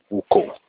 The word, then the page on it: uko: Olukumi